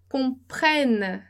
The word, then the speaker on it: comprennent: Então, para abrir a pronúncia, né, comprennent desse E, a gente vai colocar dois N's e é, de novo, o mesmo caso do baguette.